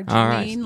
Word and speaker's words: Jeannine: Jeannine, right.